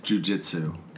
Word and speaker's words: jiu-jitsu: Jiu-jitsu.